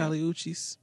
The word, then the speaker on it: Kali Uchis: Is it Kali Uchis? Uchis? – You said it right the first time. – Kali Uchis.